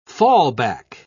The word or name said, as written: fallback